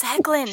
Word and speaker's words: Hoechlin: I've been calling him like Hoechlin. – It's Hecklin. Hecklin.